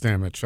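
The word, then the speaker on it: Stammtisch: Stamich? – I believe it's Stamich. I think you're good.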